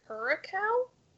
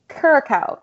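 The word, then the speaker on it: Curaçao: Curacao. – Curacao.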